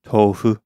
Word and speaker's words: tofu: Japanese, it would be pronounced more like tofu.